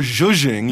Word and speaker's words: zhuzhing: What is, if I'm pronouncing it correctly, zhuzhing? – Kind of more zhuzhing. Yes, zhuzhing? Exactly.